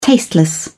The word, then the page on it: tasteless: British English